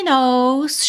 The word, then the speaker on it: know: She knows, she knows. Depois dessa aula você nunca mais vai esquecer que o K da palavra know é mudo, ok?